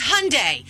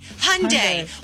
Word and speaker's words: Hyundai: And I said, Mom, it's Hyundai. Hyundai.